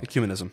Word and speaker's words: ecumenism: But for the sake of this question to begin with, like it seems to imply like certain things must occur, like I'm going to butcher the word, ecumenicism. Ugh, said it wrong. – Ecumenism. – Ecumenism. Ah, thank you.